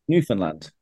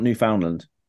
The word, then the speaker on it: Newfoundland: Did you just call Newfoundland Newfoundland? – That's how you say it. – Newfoundland? – Newfoundland. It's not Newfoundland.